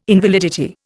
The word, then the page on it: invalidity: Indian